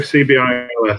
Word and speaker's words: CBILS: Because I think, correct me if I'm wrong, if you're going to go for this, how do you pronounce it, this acronym, SIP bills? This is the coronavirus business interruption loan scheme. Any way to pronounce, is there how are people pronouncing it, SIP bills? – I'd go CBILS.